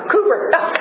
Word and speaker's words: Cowper: Have you heard of the hymn writer William Cowper? Cooper. I have his name written down, and I was like, but Cooper, okay, that's how you say it.